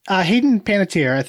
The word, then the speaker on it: Panettiere: I think Hayden Panettiere, is that how you pronounce her name? – Yes.